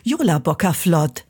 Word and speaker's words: Jólabókaflóð: Jólabókaflóð. Also man spricht es tatsächlich fast, wie man es schreibt und das ist ein Wunder im Isländischen und Deutschen. Das gibt's echt selten. – Jólabókaflóð, das merken wir uns schon, ja.